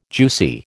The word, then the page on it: juicy: American English